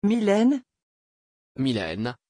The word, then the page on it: Mylène: French